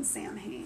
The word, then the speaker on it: Samhain: and I'm just gonna say this right now: nobody better give anybody shit for calling it fucking Samhain. It's spelled Sam Hain.